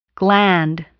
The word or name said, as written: gland